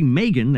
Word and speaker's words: M3GAN: And in January, Megan, that's spelled M3GAN, comes out, but Universal is already discussing a sequel to that horror film.